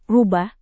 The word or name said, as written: rubah